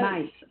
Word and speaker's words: Myss: I don't know if you're familiar with the work of Caroline Meiss. Maybe I don't pronounce her name well. – No, Meiss. – Yes. – M-Y-S-S.